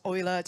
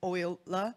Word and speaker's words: Euler: It's pronounced as Euler, just as Euler. It's not called Euler, it's wrong.